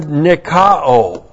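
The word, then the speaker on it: nikao (νικάω): So there's the article, and this is the participle, N-I-K-O, long O-N. This is from the verb nikao. N-I-K-A, long O.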